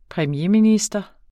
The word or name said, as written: premierminister